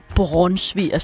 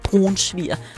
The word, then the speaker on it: brunsviger: Og hvordan man udtaler brunsviger, som Lis Ejlsø har slået mig oven i hovedet for at lære at sige. Det hedder altså ikke brunsviger. Brunsviger.